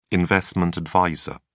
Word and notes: investment adviser: British English

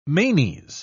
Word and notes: manes: British English

